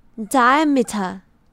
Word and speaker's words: diameter: 所以呢，r 不发音，这个字的念法就是 diameter。